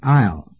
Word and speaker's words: aisle: In that blank space, write the word aisle. Aisle, A-I-S-L-E. Boy, that sure isn't spelled like it sounds, is it?